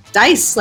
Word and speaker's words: Dais: Dais, like roll the dice.